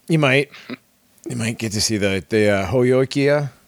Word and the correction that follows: Heyoka: Is that how you say it? – Dang it, Heyoka.